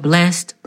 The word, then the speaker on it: blessed: It's spelled blessed, but they pronounce it blessed. Blessed is a very old Southern hymnal way to pronounce blessed, blessed.